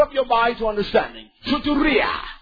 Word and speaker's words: soteria: This is how it's pronounced. It's important you understand this. – Soteria. – Soteria. – Soteria. – And that's how salvation is Soteria.